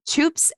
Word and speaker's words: Toops: Toops and Co. Did I say it right? – That's exactly right. Yes. Toops, I say like soup, but with a T.